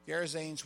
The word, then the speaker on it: Gerasenes: Okay, so when they sail over to the country of the Gerasenes, is that how you say it? Close. Gerasenes, which is opposite from Galilee, where they'd been.